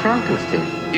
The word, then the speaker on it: Frankenstein: Dr. Frankenstein. – It's pronounced Frankenstein.